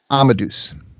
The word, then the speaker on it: Amadeus: Amadeus. – Amadeus. Amadeus. It was such a great character. – It's like Amadeus and Marmaduke together.